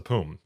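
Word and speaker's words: POUM: I believe it's pronounced the POOM, right? Mm-hmm.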